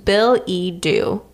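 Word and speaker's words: billet-doux: So it's spelled B-I-L-L-E-T dash D-O-U-X. And it's pronounced Bill-E-Do. Billy-Do is a love letter.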